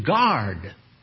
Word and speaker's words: guard: And it means to guard. Guard. G-U-A-R-D. Guard. Or you can spell it G-A-R-D, but you'd be incorrect. All right. There's a silent U. G-U-A-R-D.